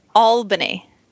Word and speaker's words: Albany: Here's how you say it. It is Albany. – Albany? – Albany. – Albany. – Albany. – Albany. – Like all with two L's. Albany.